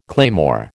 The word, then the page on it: claymore: American English